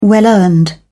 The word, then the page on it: well earned: British English